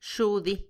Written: xudi

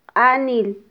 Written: qanil

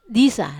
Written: isa

